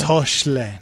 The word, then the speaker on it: Hoechlin: Tyler or Tyler Hoechlin? Does anybody know how to pronounce it? – It's Hoechlin.